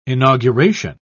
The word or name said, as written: inauguration